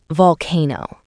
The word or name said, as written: volcano